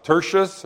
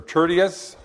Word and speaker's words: Tertius: at the hand of Tertius, or Tertius, however you want to pronounce that. There's a couple of ways that's pretty well accepted.